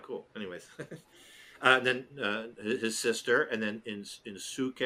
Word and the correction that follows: Inosuke: or however you say it, in Suke, I can't even say it. The boar face guy, and then Zenitsu. – Inosuke. Watch me be saying it wrong this whole time. Like, I'm so confident. – Inosuke, but it's funny. I must have spelled it wrong because I spelled it Insouke. – I'm pretty sure it's Inosuke because dude would not stop saying it in the show.